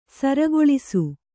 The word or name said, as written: ಸೂರೆಗೊಳಿಸು